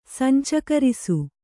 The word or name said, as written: ಸಂಚಕರಿಸು